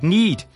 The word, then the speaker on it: knead: K is a popular letter in the baking world, starting, of course, with the silent K in the word knead, a process of folding, pulling and manipulating dough to develop gluten.